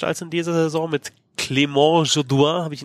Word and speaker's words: Clément Jodoin: Und das ist ja, weil er halt Franco-Kanadier ist, es ist halt natürlich die französische Aussprache. – Okay, sonst wäre es ja Clément Jodoin oder so wahrscheinlich.